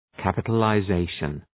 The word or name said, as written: capitalization